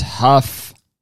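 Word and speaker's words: Hough: the name of the host isn't Julianne Hugh. Oh no. It's pronounced Huff.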